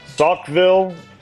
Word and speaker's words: Saukville: Is it Dodgeville, Saukville, or Reeseville? – I'm going to guess Dodgeville.